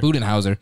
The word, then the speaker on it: Budenholzer: I mean, he came from a good organization that's been good in the past and being underneath Budenholzer. Is that how you say it? – Budenholzer. – Budenholzer.